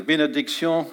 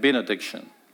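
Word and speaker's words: bénédiction: And I discovered that the French word for cursing is malédiction, And the French word for blessing is benediction, and if I give it an English pronunciation, it'd be malediction and benediction.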